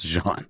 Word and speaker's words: genre: Ruh in genre is like a quick syllable. – John. – It's John.